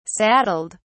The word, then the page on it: saddled: British English